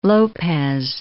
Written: Lopez

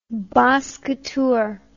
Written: basse couture

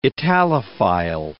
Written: Italophile

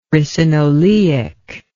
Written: ricinoleic

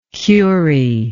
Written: curie

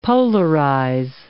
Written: polarize

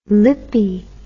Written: lippie

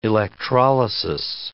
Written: electrolysis

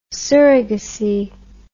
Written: surrogacy

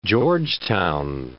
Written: Georgetown

